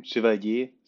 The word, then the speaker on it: Chevalier: Okay, so I have today a very cool guest here with me, David Maurice Chevalier. Have I said that right, David? – Yeah, it's almost right. Chevalier, so it's a French name actually, and we are a French-based company, but I'm German.